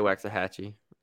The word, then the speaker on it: Waxahachie: How do you say that? Waxahachie? – I say Waxahachie. – Yes, it's spelled that way, so I'm going to say it that way.